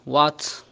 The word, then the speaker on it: what: يعني الآن عندنا كلمة اللي هي what, where, when, why, which، كل أتى فيها حرف H لكنها تكتب ولا تنطق إذا أتى قبلها حرف الدبليو.